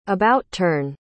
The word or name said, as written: about-turn